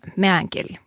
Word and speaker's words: meänkieli: Jag är alltid lite osäker på mitt uttal. Meänkieli. Meänkieli. – Jag tycker det låter bra.